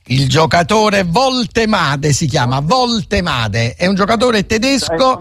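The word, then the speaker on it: Woltemade: Voltemade è semplicissimo perché la W si legge V in tedesco, come sta scritto così lo leggiamo, non facciamo errori, Voltemade.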